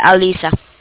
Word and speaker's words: Alyssa: Yeah, Alisa. – I know, I said spell it. – Oh, A-L-Y-S-S-A.